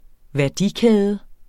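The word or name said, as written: værdikæde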